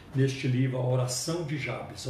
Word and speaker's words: Jabes: Ela não tem, é, não é muito comentada é, a oração de Jabes, alguns falam Jabes, eu vou usar a pronúncia Jabes.